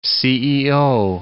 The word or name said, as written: CEO